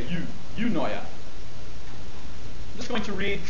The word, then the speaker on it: Eunoia: I'm going to read to you some excerpts from my book entitled Eunoia. Eunoia is spelled E-U-N-O-I-A.